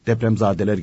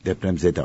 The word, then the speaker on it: depremzede: Zade ile zedeyi anlamıyor mesela değil mi? depremzadeler geldi diyor. depremzadeler. Depremzede ayrı, zede ayrı, zade ayrı. Depremzade deprem çocukları demektir. Depremzede depreme e, maruz kalmış olan, mağdur olanlar demektir.